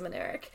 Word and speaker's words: Minarik: Would you like to on the last episode, I didn't know how to say her last name, which is also just hilarious. Would you like to introduce yourself? – Yeah, I am Alyssa Minarik. And Ari, you did a great job of introduction.